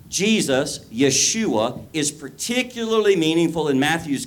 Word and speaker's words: Yeshua: Yeah, that's how you say it. Yeshua.